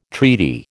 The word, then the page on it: treaty: American English